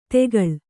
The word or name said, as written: ತೆಗಳ್